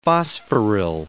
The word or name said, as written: phosphoryl